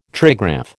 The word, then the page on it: trigraph: American English